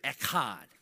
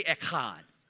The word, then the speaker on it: echad: The Hebrew word is echad. Say echad. Okay, you didn't have enough in it, all right? Seriously, man, Hebrew's got it. I mean, it's a guttural language, all right? If you say it right, then your neighbor in front of you should know. It should get on him a little bit, all right?